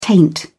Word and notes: taint: British English

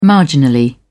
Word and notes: marginally: British English

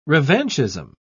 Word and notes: revanchism: British English

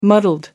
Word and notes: muddled: American English

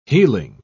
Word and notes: heeling: British English

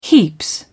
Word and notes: heaps: British English

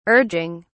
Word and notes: urging: British English

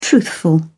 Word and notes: truthful: British English

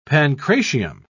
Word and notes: pancratium: British English

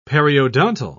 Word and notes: periodontal: British English

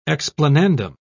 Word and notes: explanandum: British English

